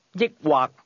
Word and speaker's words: 抑或: 抑或。抑或。抑或。This word is pronounced with a combination of high and low level tones. Say, or. – 抑或。抑或。